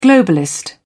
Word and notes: globalist: British English

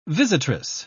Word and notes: visitress: British English

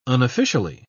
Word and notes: unofficially: British English

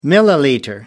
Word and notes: milliliter: British English

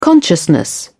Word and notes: consciousness: British English

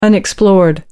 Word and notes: unexplored: American English

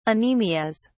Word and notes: anemias: British English

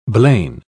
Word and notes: blain: British English

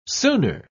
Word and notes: sooner: British English

